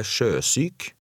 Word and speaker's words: sjøsyk: In number 17, the last word has two syllables. Read number 17. – Han ble sjøsyk. Sjøsyk.